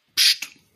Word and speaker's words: Psst: Heißt nicht so Psst Games, wie das ausgesprochen, ne? – Psst. Ja.